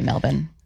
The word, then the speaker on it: Melbourne: It's actually Melbourne.